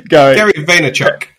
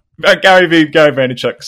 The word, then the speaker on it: Vaynerchuk: Gary Vaynerchuk. – There you go. Gary Vaynerchuk, same guy.